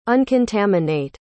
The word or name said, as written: uncontaminate